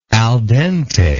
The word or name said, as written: al dente